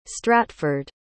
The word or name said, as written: stratford